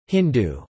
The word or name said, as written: Hindu